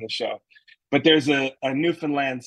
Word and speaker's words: Newfoundland: You know, in my good old New England accent, I would probably pronounce it like Newfoundland or Newfoundland or something like that. Mm-hmm. But they're very, very specific that it's Newfoundland. Like, oh, wow. Land is where.